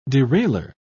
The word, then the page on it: derailleur: British English